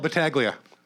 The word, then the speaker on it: Battaglia: Woody Battaglia comedy, but it's spelled Battaglia. – It's spelled with a silent G, just like lasagna. That's how the Italian language works. I didn't make it up.